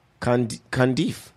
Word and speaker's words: Cundieff: Cundiff? – Hey, man. – C-U-N-D-I-E-F-F. – Cundiff. That's what I was saying. – Cundiff. – Cundiff? – Yeah. – Cundiff? – So, I mean... – Rusty Cundiff.